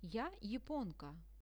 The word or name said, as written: ja japonka